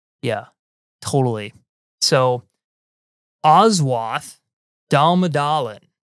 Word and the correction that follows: Damodaran: Damodaran. – Damodaran.